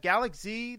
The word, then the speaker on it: Galak-Z: So the second game is Galaxy the Dimensional.